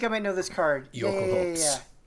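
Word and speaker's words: Jökulhaups: It's pronounced Yokul Hopes, but it's Yokul, like J-O-K-U-L, like from Norse, H-A-U-P-S, Yokul Hopes.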